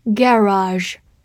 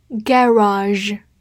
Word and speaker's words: garage: Garage, G A R A G E, garage, G A 发 g a g, R A r a r, G E 发 r, garage, garage。这个词其实有很多种发音，除此之外还可以发 garage，或者 garage，garage，garage 都是可以的。